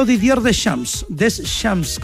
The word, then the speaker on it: Didier Deschamps: Hoy habló Didier Deschamps, como sería la pronunciación correcta, del director técnico campeón del mundo, como entrenador y como jugador.